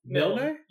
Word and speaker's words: Milne: I think that's how you say that, right? Milne? – Milne? Yeah, it's pronounced exactly the same as the guy who wrote Winnie the Pooh.